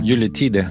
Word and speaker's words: Yuletide: Je le prononce mal, je suis sûre, Herman Dune. – Yuletide. – Yuletide.